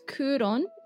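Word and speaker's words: Kuron (九龍): You'll notice that the city is pronounced as Kuron in Japanese. That's how the characters are saying it because of the kanji, which means nine dragons, which is a really cool little note, I guess, to make.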